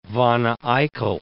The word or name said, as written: Wanne-Eickel